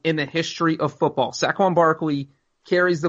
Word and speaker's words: Saquon: Saquon Barkley.